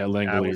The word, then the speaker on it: Langeliers: So, and Langeliers, is that how you pronounce it? – Yeah, Langeliers was one of them.